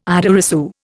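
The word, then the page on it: idée reçue: Indian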